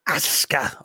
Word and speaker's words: Asuka: It was Rhea Ripley defeating Asuka. Asuka, I go out of my way to say that correctly now.